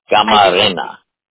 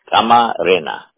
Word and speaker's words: Camarena: Okay, my last name is spelled just like it sounds, if you understand Spanish. Camarena. – Camarena? – Camarena.